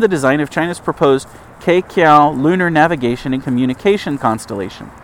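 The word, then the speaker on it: Queqiao: The Tiandu 1 and Tiandu 2 satellites are due to launch along with Keqiao. It looks like Keqiao. I'm sure in Chinese it's pronounced differently. It's Q-U-E-Q-I-A-O, a lunar communications relay satellite to support upcoming Chang'e lunar Farside and South Pole missions.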